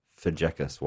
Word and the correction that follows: Fazekas: way. Was that close? – Fazekas.